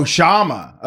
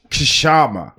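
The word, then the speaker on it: Kshama: And remember, the K is silent. – Oh, Shama. Okay. I thought it was Kashama.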